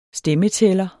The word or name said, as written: stemmetæller